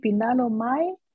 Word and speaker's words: Pindano Mai: Ik weet niet of ik het goed uitspreek. Pindano Mai van de Rua Brothers. – Ja, heel goed uitgesproken.